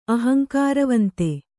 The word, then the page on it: ಅಹಂಕಾರವಂತ: ahaŋkāravanta